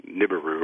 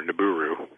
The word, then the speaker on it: Nibiru: And they come from a planet called Nibiru or Nibiru. And there's two different ways to pronounce it for you.